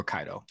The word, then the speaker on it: Kaido: The fact that currently in One Piece, he did actually switch it to his left to fight against Kido. Or Kaido, that's how it's pronounced.